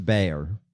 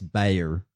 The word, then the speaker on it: Bexar: It's pronounced Bayer. – Okay. – B-E-X-A-R. – Right. – I know, I lived in Texas. It's Bayer. – That's a thing? – Yeah. – Okay. – B-E-X-A-R is Bayer.